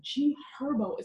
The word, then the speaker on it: G Herbo: Let's start off with G Herbo.